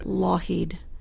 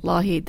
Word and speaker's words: Lougheed: Lockheed. Yeah, Lougheed. Lougheed. There's no K.